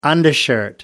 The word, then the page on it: undershirt: American English